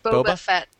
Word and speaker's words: Boba Fett: The rumor is that they're going to one's going to focus on Boba Fett? How do you pronounce that? I've never seen it. – Boba Fett.